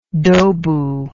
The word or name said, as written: dobu